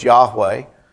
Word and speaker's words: Yahweh: Probably the best way to pronounce it that we know is Yahweh.